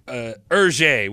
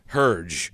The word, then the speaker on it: Hergé: So the author, his pen name of the author of Tintin, his pen name is Hergé, which I've always pronounced Herge. That's how it's spelled, and I'm stupid and American.